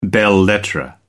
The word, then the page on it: belles-lettres: American English